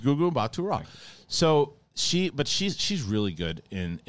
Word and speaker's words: Gugu Mbatha-Raw: And then I always pronounce her name wrong. But it's Gugu Mbatha-Raw. Is that how you? – Gugu Mbatha-Raw. – Yeah. So I was right? Gugu Mbatha-Raw. I was not wrong.